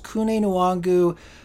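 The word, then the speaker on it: Kene Nwangwu: Okay, it's spelled kind of like Kine Nwangu, but it's pronounced Kune Nwangu.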